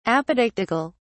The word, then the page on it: apodictical: British English